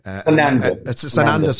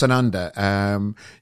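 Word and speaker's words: Sananda: You call him in the book Sonada. Terence Trent D'Arby. – Sonanda. – Sorry, my pronunciation's bad. So it's Sonanda.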